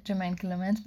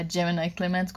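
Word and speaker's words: Jemaine Clement: در مورد تلفظ اسم جیمین کلمنت بگم یه توضیح بدم که چون که He's my old crush. من از اول اسمشو اشتباه فهمیدم و جیمینای کلمنت گفتم و این کلن افتاده دهنم و جور دیگه نمیشونم. زیباتر نیست واقعا.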